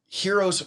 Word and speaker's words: Hxeros: It's called Super, and it's kind of still pronounced Heroes, but it's spelled H-X-E-R-O-S. So it's Heroes and, like, Eros and, like, Zer, I don't know.